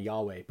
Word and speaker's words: Yahweh: We pronounce it kind of Yahweh, but it was a word that the Jewish folks, they did not use.